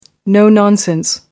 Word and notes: no nonsense: American English

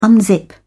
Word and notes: unzip: British English

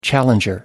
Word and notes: challenger: American English

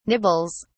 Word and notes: nibbles: British English

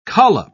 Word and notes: collop: British English

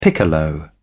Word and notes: piccolo: British English

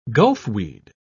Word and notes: gulfweed: British English

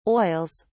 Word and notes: oils: British English

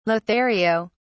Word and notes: lothario: British English

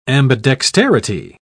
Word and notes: ambidexterity: British English

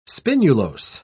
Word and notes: spinulose: British English

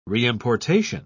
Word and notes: reimportation: British English

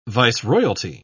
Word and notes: viceroyalty: British English

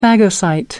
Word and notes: phagocyte: American English

